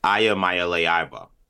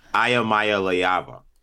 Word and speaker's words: Iamaleva: I'm going to give this a shot here, but this is how you're supposed to pronounce it. Iamaleva. – Yeah, right. We were missing a syllable. – It's the ah. It's the ah before the va that you missed.